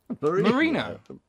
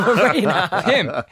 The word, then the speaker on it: Moreno: You even said it wrong when I told you the answer. It's Moreno.